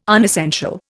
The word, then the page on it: unessential: Indian